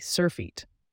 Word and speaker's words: surfeit: It's spelled S-U-R-F-E-I-T, surfeit. This one is a noun, and it means an excessive amount of something. Remember, surfeit sounds like surface it, as if you have so much of something that it surfaces over the top.